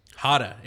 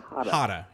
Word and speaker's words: Jara: Hatta. It's pronounced Hatta. Yeah. – But it's spelled J-A-R-A.